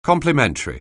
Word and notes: complementary: anglais

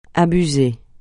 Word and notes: abuser: francais

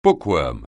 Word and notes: bookworm: anglais

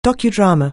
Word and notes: docudrama: anglais

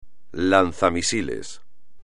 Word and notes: lanzamisiles: espagnol

